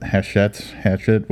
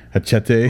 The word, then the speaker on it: Hachette: So the hot topic right now is the dispute between Amazon and I'm going to say it wrong, but Hachette, whatever it's called, the one of the top five publishers. – Hachette. – Close enough.